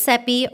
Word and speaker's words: Seppi: My name is Seppi, rhymes with Peppy, and I'm your host.